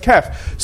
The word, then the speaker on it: Kaf: pronounced Kaf.